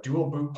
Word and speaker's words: Dual Boot: She's with Dual Boot. I hope I say that right. Dual Boot Partners. Did I say it correctly? – You did.